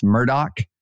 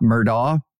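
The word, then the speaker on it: Murdaugh: And again, thanks to listeners who sent in and told us it is pronounced Murdoch, not Murdaw, but the Murdoch murders in South Carolina.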